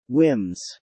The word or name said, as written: whims